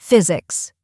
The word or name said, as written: physics